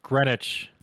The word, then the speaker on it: Greenwich: Why is there a W in there? – It's pronounced Greenwich.